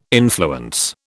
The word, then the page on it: influence: British English